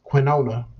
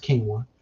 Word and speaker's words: quinoa: Brown rice and quinoa, not quinona, black people, quinoa. It's pronounced quinoa.